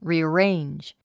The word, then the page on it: rearrange: rìːəréindʒ; リィアレェンジ